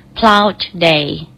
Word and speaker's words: Plough Day: plough day